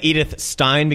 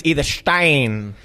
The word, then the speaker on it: Edith Stein: Well, I've always said Edith Stein because I'm a filthy public school kid, and I don't know how to say these things, but apparently it's Edith Stein.